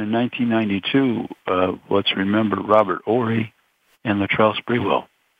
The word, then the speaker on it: Horry: Robert Horry. Horry, I think it is pronounced. – Horry, right. Yeah, Silent H. Horry, yeah.